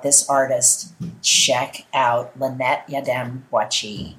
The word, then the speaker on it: Yiadom-Boakye: Her name is Lynette Yadom Boachi.